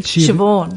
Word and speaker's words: Siobhan: Siobhan.